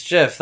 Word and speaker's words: GIF: I think it actually is JIF. I'm pretty sure that's what the It's 100%. It's Jif. – I think you're right. Yeah. – Yeah. The guy who created that protocol said it's pronounced Jif.